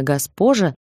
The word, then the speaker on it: Госпожа: Она всегда приезжает без предупреждения, строит всю семью и, как истинная госпожа, получает огромное удовольствие. Фамилия такая — госпожа, только ударение на вторую «о».